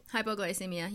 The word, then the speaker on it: hypoglycemia: Hypoglycemia. How do you say it? – Hypoglycemia.